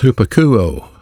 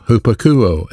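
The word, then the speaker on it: hupakouo (ὑπακούω): That Greek verb is the word pronounced hupakouo, hupakouo, H-U-P-A-K-O-U-O.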